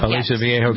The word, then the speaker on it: Aliso Viejo: Is that the way to pronounce that? – Yeah, Aliso Viejo, California.